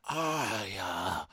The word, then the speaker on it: Arya: Let me pronounce it properly for everyone. Arya. As your mother would say it.